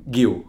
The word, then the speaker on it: Guiu: Oh, how do you say it? – Guiu. – Guiu.